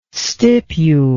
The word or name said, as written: stipule